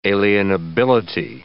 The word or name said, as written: alienability